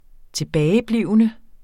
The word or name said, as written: tilbageblivende